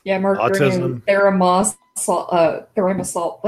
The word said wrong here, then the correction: thimerosal: whatever that's pronounced. – Thimerosal. Yeah, Thimerosal.